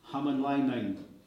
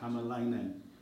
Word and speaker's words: Hämäläinen: There's a really fantastic book here called Comanche Empire by Pekka Hämäläinen. I said it wrong, but Hämäläinen.